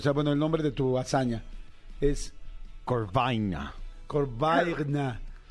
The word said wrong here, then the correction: Corbaigna: Corvaigna. C-O-R-B-A-I-G-N-A. Corvaigna.